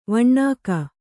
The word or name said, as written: ವಿನಕೆ